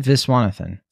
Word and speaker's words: Viswanathan: Geraldine Venezuela, is that how you pronounce it? – Geraldine Viswanathan. – Viswanathan, yeah.